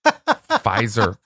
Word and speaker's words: Pfizer: Uh, and there's also something about a silent P that I just love. Pfizer. You want to say it, Pfizer, but you don't.